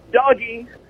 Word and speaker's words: Doge: First of all, it's pronounced doggy.